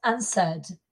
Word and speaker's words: answered: Ah, diríamos answered y hay que oír la D, muy importante. Mm. Me gusta que hayas elegido este porque ansa es un verbo que no se pronuncia fácilmente porque hay una mm. – W ahí. No es ansua, nunca es ansa. Y en pasado, ansad, con una D.